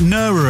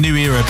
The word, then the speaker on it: New Era: I pronounced it Nura earlier, didn't I? It's actually New Era, Birds in the Sky.